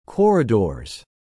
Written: corridors

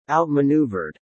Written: outmaneuvered

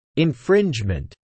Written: infringement

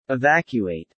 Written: evacuate

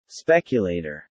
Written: speculator

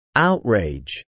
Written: outrage

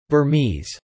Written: Burmese

